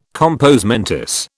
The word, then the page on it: compos mentis: British English